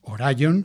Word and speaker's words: Orion: Bueno, pues seguimos aquí en The Walk y hemos escuchado a Jimmy Ellis Orion, Orion, como se pronuncia realmente, bueno, pues un gran cantante con una voz muy, muy similar a la de Elvis Presley.